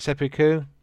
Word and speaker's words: seppuku: They do is it seppuku? How do you say it? Seppuku.